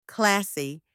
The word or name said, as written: classy